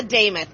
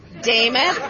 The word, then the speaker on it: Damoth: And as your next nominee as a Republican for governor, my name is Lisa Damoth. I'm going to give you the easiest way to understand that. It rhymes with Joe Namath. Damoth, Namath. It's not real hard.